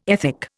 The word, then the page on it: ethic: Indian